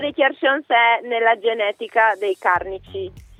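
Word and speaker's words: cjarsons: per cui pian piano insomma sto Charsons. E poi, insomma, esatto. Poi c'è anche l'accento, la O deve essere chiusa, è divertente la fonetica.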